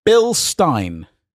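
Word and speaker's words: Bilstein: Bill Stein. It rhymes with pine, blah, blah, blah.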